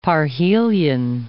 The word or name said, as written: parhelion